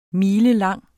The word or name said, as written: milelang